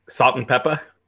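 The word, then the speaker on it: Salt-N-Pepa: Salt and Peppa.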